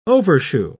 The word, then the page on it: overshoe: British English